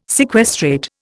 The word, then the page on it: sequestrate: Indian